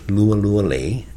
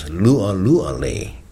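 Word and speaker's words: Lualualei: I come from a valley known as Lualuale. Other people pronounce it, not many people, but other people pronounce it as Lualuale, with a different forcefulness to it.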